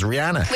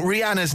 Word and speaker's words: Rihanna: Rihanna. It's not Rihanna.